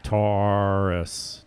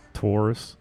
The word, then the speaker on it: Taurus: I want to say the A as an A, but it's an or tor or, like the A is silent almost. – Taurus. Taurus.